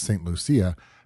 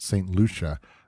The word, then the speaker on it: St. Lucia: Now, for me, growing up in Southern California and taking that full 3.5 years of high school Spanish, I was confidently processing the name as St. Lucia, but in actuality, it's pronounced St. Lucia.